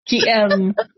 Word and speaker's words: Qigong: I always say it wrong. That martial art kind of movement thing. – Yeah. Qigong. – Qigong. Thank you.